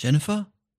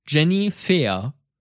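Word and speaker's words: Jenny Fehr: Jennifer? Jenny Fair. Okay, warte mal. Jenny, Jennifer könnte man es natürlich aussprechen, aber hier steht Jenny mit Y und dann F-E-H-R. Jenny Fair.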